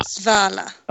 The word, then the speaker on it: Svala: It looks like it should be Salvia, but it's not. – Svala.